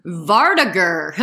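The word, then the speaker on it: vardøger: Vardagr. There are many pronunciations, but that's what I found is the common U.S. pronunciation.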